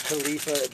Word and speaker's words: Kaleafa: Because their name is Khalifa, but it's spelled K-A-L-E-A-F-A.